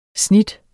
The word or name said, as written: snit